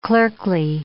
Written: clerkly

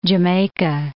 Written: jamaica

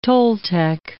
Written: Toltec

